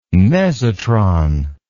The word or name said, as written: mesotron